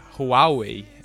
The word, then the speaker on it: Huawei: É, talvez assim, para mencionar uma fabricante que que a gente fala pouco, a, a chinesa Huawei, que é Huawei, é, mais ou menos, né? Acho que Huawei é a pronúncia correta, mas a gente consegue ler como Huawei.